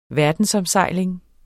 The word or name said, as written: verdensomsejling